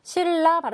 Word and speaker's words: 신라: 신라도 마찬가지죠. 신라 발음하면은 받침은 원래는 니은이지만 발음을 하면 리을로 바뀌게 되죠.